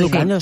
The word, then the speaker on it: educando: ¿Educándose? ¿Lo he dicho bien? – Sí, educando, sí, sí.